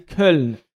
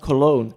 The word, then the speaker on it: Köln: From one side is Nice, the other is FC Köln, I would say. Like a German, FC Köln, not Cologne.